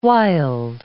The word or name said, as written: wild